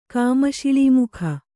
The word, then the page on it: ಕಾಮಶಿಳೀಮುಖ: Kannada